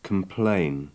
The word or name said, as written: complain